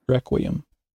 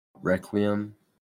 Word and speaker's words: Requiem: Requiem. – Requiem.